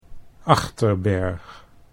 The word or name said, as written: Achterberg